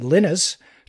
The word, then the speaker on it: Linus: His name was Linus, or as he pronounces it, Linus Torvalds.